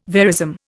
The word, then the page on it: verism: Indian